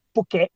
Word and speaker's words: Poké: E a sorpresa anche una, una cosa culinaria che sono le Hawaii Poké, che si, che si legge Poké, e che in, in, Giappone, in, in hawaiano significa sì.